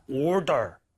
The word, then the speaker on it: order: 그래서 근데 이게 플랩을 안 내면 한마디로 리을처럼 발음을 얘를 안 하면 엄청 어색해져서 그래요. – 네. – Order, order.